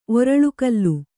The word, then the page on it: ಒರಳುಕಲ್ಲು: Kannada